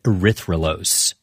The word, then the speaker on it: erythrolose: And they scanned it for radio signals of different molecules, which is how they found a four-carbon sugar called erythrolose.